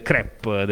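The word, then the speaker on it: crêpe: Abbiamo anche un momento culinario oggi, perché come si vede bene dalla webcam, sono state portate delle crepe, delle fantastiche crepe.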